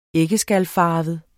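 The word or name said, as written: æggeskalfarvet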